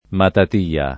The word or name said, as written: Matatia